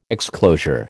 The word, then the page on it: exclosure: American English